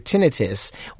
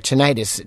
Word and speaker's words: tinnitus: And if you were in the Marine Corps for 10 years and they didn't offer you protection, then your tinnitus. Or tinnitus, doesn't matter which way you pronounce it, tinnitus.